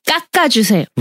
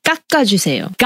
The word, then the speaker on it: 깎아주세요: 깎아주세요. – Right, so it's one fast ga and one slow ga. – Yeah, very strong emphasis on the G though. 깎아주세요.